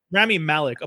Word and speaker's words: Rami Malek: Sorry, Rami Malek. Apologies. That's how you say his name.